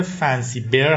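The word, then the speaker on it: Fancy Bear: برای توضیح هم بدم که تلفظ صحیحش گروه فنسی بیر هست، به معنی خرس. اون بیر که من بارها گفتم آبجوی خودمونه.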